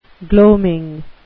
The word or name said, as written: gloaming